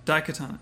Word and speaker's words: Daikatana: Daikatana.